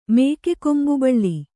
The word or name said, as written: ಮೇಕೆಕೊಂಬುಬಳ್ಳಿ